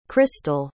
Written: chrystel

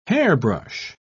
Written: hairbrush

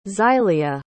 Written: xylia

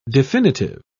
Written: definitive

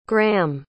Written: graeme

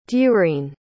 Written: durene

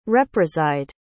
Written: represide

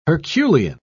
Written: herculean

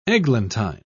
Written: eglantine

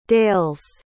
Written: dales

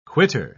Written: quitter

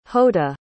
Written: hotah

